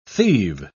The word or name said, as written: thieve